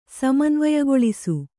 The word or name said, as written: ಸಮನ್ವಯಗೊಳಿಸು